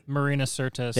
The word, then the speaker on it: Marina Sirtis: Say her name for me because I'll butcher it. – Marina Sirtis. – Thank you. That one.